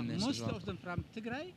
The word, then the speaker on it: Tigray: Is this this group here that you've got, is everyone from, how do you say it, Tigray, is that right? – Tigray, yeah.